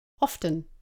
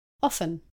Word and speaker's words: often: Some people pronounce often with a T, often, and others don't pronounce the T, often.